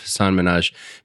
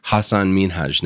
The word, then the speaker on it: Hasan Minhaj: You know, he used to go by the more American pronunciation of Hasan Minhaj, but recently reverted back to the correct pronunciation. So he prefers Hasan Minhaj now.